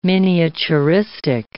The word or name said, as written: miniaturistic